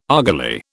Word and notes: argali: British English

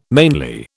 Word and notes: mainly: British English

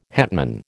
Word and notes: hetman: American English

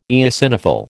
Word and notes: eosinophil: American English

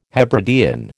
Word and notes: Hebridean: American English

